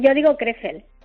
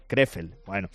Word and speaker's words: Krefeld: ¿Cómo se pronuncia esta ciudad alemana? – Sí, bueno, yo digo Krefeld. – Krefeld, bueno.